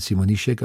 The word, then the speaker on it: Simonischek: Die Simonischek, das wird ja in Slowenien Simonischek ausgesprochen, wird auch nicht mit SCH geschrieben, sondern mit einem S und einem Häkchen drauf.